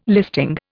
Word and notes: listing: Indian

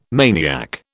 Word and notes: maniac: British English

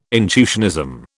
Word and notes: intuitionism: British English

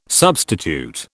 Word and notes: substitute: British English

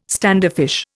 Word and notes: standoffish: Indian

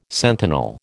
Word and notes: sentinel: American English

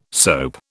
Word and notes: soap: British English

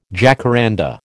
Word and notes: jacaranda: American English

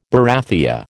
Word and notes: barathea: American English

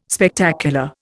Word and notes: Spectacular: Indian

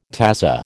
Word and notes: tazza: American English

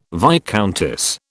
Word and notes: viscountess: British English

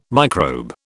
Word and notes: microbe: British English

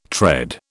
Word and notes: tread: British English

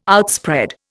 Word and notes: outspread: Indian

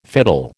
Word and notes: fiddle: American English

